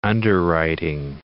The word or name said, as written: underwriting